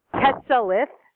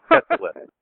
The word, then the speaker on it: Quetzalith: It's Quetzalith. So like Quetza, like pizza, and then lith, like lithograph. – Quetzalith.